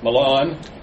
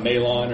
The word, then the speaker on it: Mahlon: Mahlon. Like, get off my lawn, or whatever. That is how you say that name, not Malon or something like that.